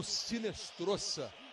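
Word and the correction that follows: Hinestroza: A pronúncia com R, né? Se escreve com H, mas a pronúncia em espanhol é com R.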